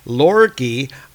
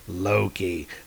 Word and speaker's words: Loki: Anyway, Robert is saying I need to watch my pronunciation of the name Loki. And the reason why is because I've been coming a little bit too close to pronouncing it in a way that sounds like a naughty word in Icelandic. So I don't want to say Lorki, I want to say Loki.